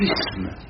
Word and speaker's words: isme: Mais la prononciation correcte, c'est isme. Bah, il faut travailler à le maintenir. – Sur de la loi, les deux. – Et oui, non, je sais bien, vous prononcez isme. – Et lui qui est au sud encore plus. – Et oui, oh, ça, réellement, oui. – Les Anglais ont énormément de mots qui commencent par sme et jamais ils n'ont de tendance à prononcer un z à la place.